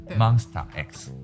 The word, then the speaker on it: Monsta X: 来，我念给你听哦。 Mon Monsta，就是它。 Star. 对，Monsta X。 – X。 Monsta X。 – 对对对对，念标准点是这样。 – 哦。 – 对。然后我喜欢的第三个歌手叫做元虎。 – 元虎。 – 对。然后他就是我说的从 Monsta X 里面这个团体里面单飞了一个成员。